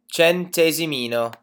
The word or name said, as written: centesimino